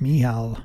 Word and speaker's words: Michal: Mihal, spelled M-I-C-H-A-L, but pronounced Mihal, Leah.